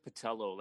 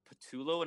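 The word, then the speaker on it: Pattullo: How is it pronounced? What's the official pronunciation? – I would go with Patello. Like, people will call in and they'll say Patullo, and I'm always just like, that just doesn't sound right.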